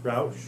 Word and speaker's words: Rausch: Signed, John and Stacy Rauch." Am I saying that right? – Rausch.